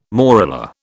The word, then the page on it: morula: British English